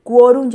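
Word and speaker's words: quórum: Y de hecho se trata de una palabra grave, al, españolizarla, digámoslo así, se trata de una palabra grave terminada en consonante, así que lleva acento ortográfico. Quorum lleva acento en la O.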